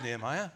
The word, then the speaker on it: Nehemiah: Very much in keeping with our current series in Nehemiah, and that is the correct way to pronounce it. I would say so. I'd say it was Nehemiah, but you feel free to pronounce it differently and be wrong.